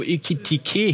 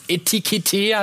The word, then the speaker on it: Ekitike: Auf vielfachen Wunsch einer einzelnen Person haben wir nochmal die Aussprache von Hugo Ekitike hier verbaut, der ja, nicht Etikette heißt, nee, Ekitike.